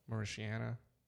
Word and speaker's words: Mauritania: Mauritania. – Yeah. Mauritania.